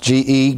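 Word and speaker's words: ge (γῆ): All the world comes from the Greek word ge, pronounced G-E, ge, and it means the inhabitants of the earth.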